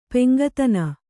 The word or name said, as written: ಪೆಂಗತನ